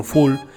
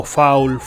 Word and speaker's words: foul: aunque en la Argentina todavía se alterna con full, incluso a veces pronunciado como foul, full o foul.